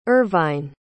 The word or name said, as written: irvine